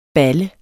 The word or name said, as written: balle